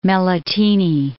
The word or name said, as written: Melitene